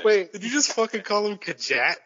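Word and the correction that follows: Khajiit: That's how you pronounce, right? – They're Kajit. Kajit has wares if you have coins.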